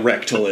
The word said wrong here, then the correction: reticle: is. I mean, wait, what? Reticle? – Red. – As the smaller the rectal is, you want a small rectal. – Spell it. – Yeah. – R-E-T-I-C-L-E.